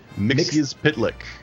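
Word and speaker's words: Mxyzptlk: Mixius Pitlick.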